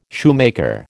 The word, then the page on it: shoemaker: American English